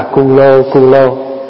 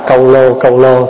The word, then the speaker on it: câu lô: Có chỗ thì người ta âm là cu lô, nhưng mình đọc là câu lô thì âm nghe nó hay hơn, nó nhẹ hơn.